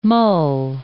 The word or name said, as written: mull